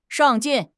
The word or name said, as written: 上进